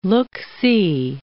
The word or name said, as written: look-see